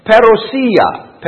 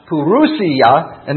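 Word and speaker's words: parousia: That word is pronounced perousia, perousia, and it's just simply the Greek term that returns to the second coming. Now, sometimes you'll hear it pronounced purousia, and that's something else.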